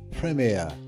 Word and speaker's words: Premier: We don't say it Premier, but I love the way the British say it. They put emphasis on the Prem, and they put emphasis on the air. Premier. They're both important syllables.